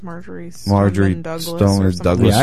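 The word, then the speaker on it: Marjory Stoneman Douglas: You know, and I guess Major Amlum School, Major, however you say it. How do you say it, honey? Major Douglas School? Is that what it is? – I think it's Marjorie Stoneman Douglas School.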